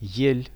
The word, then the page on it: ell: Russian